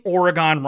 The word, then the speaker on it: Oregon: Someone saying they liked the show, but I was pronouncing Oregon wrong. Apparently it's Oregon. If so, I've been saying it wrong my entire life. I posted on Twitter. Some people said I was saying it wrong. Some said I was saying it right. So I might just alternate. I might just say Oregon, Oregon.